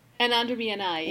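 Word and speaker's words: Anaander Mianaai: Can you tell me a little bit about how you came up with, and I'm sorry if I butcher the pronunciation, Anander Minai, Anander? – Anander Minai, yeah.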